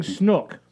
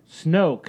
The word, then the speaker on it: Snoke: You're saying Snook. – Snook. – Snoke. – Snoke. – Like, rhymes with smoke? – Snoke.